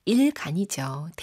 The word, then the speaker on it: 일간: 황재성님이 매일 다르게 들리는데 일카 일반인가요? 일간인가요? 생각하는 대로 들려서 매번 다르게 들리네요. 흥흥 하셨는데 제 발음이 이상했나 봐요. 일반 카페인, 일간 카페인. 네, 이거 일반 카페인도 재밌는데? 라는 생각이 갑자기 듭니다. 일반적인 카페인인가? 그럼 특별 카페인이 따로 있나? 모르겠습니다. 우리가 이제 전달하는 것은 일간이죠. 데일리, 매일매일, 이제 매일매일 오늘 하루에 커피 드시듯이 그렇게 배달한다라는 느낌으로 이렇게 지금 일간 카페인을 드리고 있습니다.